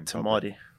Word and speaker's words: Te Maire: Te Maire. – Te Maire. – Te. – Te. – Like te, like a piece of paper. Te. Te. Mai, like mine.